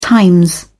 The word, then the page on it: times: British English